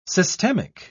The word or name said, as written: systemic